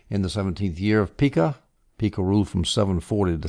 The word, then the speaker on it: Pekah: And Pekahiah, and then Pekah, P-E-K-A-H. Could pronounce that Pekah, I guess, but I'm pronouncing Pekah.